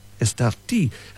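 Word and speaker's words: Estartit: The last three letters spelt T-I-T, but in Spanish, of course, you don't say it like that. You say Estarti.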